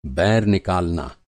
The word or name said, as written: बैर